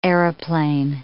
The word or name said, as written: aeroplane